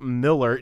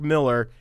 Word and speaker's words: Millar: And I previewed this story that about Mark Millar. And as soon as I said Millar, Joe corrected me and said, no, it's Millar.